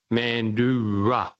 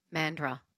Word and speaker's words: Mandurah: It's an indigenous name, and it's pronounced Mandurah. – Yeah, Mandurah. Mandurah.